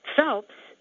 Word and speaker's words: Phelps: I think you might know how to spell Diane, D-I-A-N-E. My middle name, which is Phelps, P-H-E-L-P-S, is the same as the Olympic swimmer, Michael Phelps.